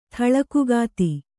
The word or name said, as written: ಥಳಕುಗಾತಿ